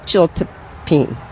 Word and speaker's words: chiltepin: Yeah, it's pronounced Chiltepin, but it's C-H-I-L-T-E-P-I-N.